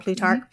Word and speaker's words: Plutarch: I also, when I was researching this and looking at it, that and I'm going to butcher his name, the historian Plutarch. Is that what you said his name Plutarch. Was? Plutarch.